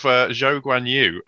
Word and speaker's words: Zhou Guanyu: But we have Zhou Guanyu. That is apparently how he prefers to have it pronounced.